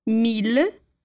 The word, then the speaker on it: mille: No entanto, para as palavras mille, ville e tranquille, a gente vai pronunciar os dois L's com o som de L, normal. Então, lembra lá: ville, mille.